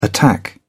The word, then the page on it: attack: British English